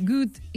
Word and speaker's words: Goethe: En alemany com es pronunciaria bé? – Goethe. – Ah, com good en anglès, com good. – Si fos bo. – Good. Sí, ja ho dius bé.